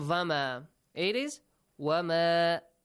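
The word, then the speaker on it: wama (وما): Sometimes you might pronounce the letter waw as vowel. Vama. It's not correct. Vama. Vama is not correct. Right? Because we don't have va sound in Arabic. It's wa, wa. Wama, not vama.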